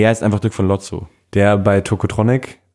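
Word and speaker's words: Lowtzow: Der erste Song ist Alles zieht vorbei mit Dirk von Lofzo. spricht man ihn so aus? – Äh, Lotzo einfach. Lo, Lotzo. Aber er hat sehr viele Ws in seinem Namen.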